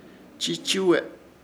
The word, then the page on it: Chichuwe: Ishir Chamacoco